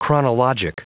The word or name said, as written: chronologic